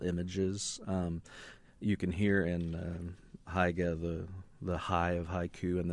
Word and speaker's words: haiga: The Writers' Center of Indiana has a two-session class this month that will teach you the Japanese form of haiga, which combines haiku and visual art. Sharon Gamble invited instructor to our studio to learn more. – Haiga? Am I saying it correctly? – Haiga. Yeah.